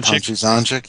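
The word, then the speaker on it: Tonči Zonjić: It was a 5-issue miniseries written by Catherine Immonen, wife of Stuart Immonen, illustrated by, and I don't know if I'm saying this right at all, but Tonchi Zonchik. – Tonchi Zonchik, yeah, okay.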